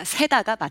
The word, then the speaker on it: 세다: 일단 발음은 기가 세다가 맞고요. 장음입니다. 세다. 길게 말을 해야 돼요.